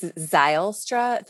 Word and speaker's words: Zylstra: It's Zylstra. Think like Zylstra. Xylophone. It's a long okay. I sound.